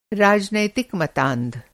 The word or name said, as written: मतांध